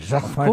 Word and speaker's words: Jacquemart-André: Jacquemart-André.